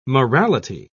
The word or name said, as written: morality